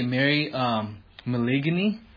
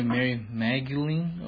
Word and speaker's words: Mary Magdalene: Just like a long time ago, I used to say Mary Maligny, and say Mary Magdalene, or whatever that name is.